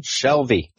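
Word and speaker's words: Shalvey: Next up, Image, Injection number 8, writer Warren Ellis, art by Declan Slavy. – Shelby. – Thank you. – I talked about him in the... – That's in the right. Okay. My mistake. It looks... It's spelled differently than it sounds.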